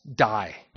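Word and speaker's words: die (δεῖ): The word there in Greek, where we get the English word must, is actually pronounced die.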